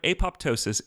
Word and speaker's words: apoptosis: the word apoptosis is most definitely pronounced with two audible P's.